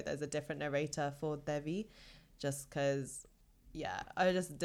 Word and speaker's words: Devi: Devi, like, yeah, almost like D-A, and it's more of a D-H, like Devi.